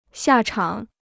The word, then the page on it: 下场: xià chang